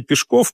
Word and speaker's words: Пешков: Говорит: «Ну а если бы за мной другой псевдоним укоренился, ведь Максим Горький – это же его псевдоним, его звали Алексей Пешков, причем ударение на последний слог.